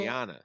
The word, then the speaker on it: Rihanna: I've heard it twice on two podcasts this week that her name is actually Rihanna. – Well, Rihanna. That's not fair, because we knew her as Rihanna growing up in Cleveland, and we probably got...